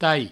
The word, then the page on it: talh: provençal; tai